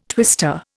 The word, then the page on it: twister: Indian